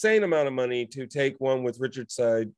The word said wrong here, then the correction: Sade: Side, that's right. That's how it's pronounced.